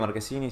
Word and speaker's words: Marchesini: Bueno, Marquesini depende, ¿viste? Si estamos en Italia, Marquesini, si estamos acá, Marchesini.